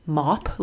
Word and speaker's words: WAP: Because I've heard, so Leslie made a good point that you don't say mop, like you say like map and trap and lap, like in terms of like the way it's spelled, like L-A-P is lap. – Yeah. – And he's like, shouldn't W-A-P be WAP? – Oh.